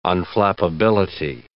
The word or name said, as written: unflappability